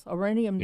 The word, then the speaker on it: Oranienbaum: And how do you say this? Oranienbaum? Something like that? – Oranienbaum. – I was close. Oranienbaum.